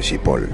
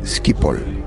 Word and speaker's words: Schiphol: Descubrí que Schiphol no se dice Schiphol. En holandés se dice Schiphol. Por lo cual, durante muchísimos años pronuncié mal el nombre de este aeropuerto, lo cual es normal.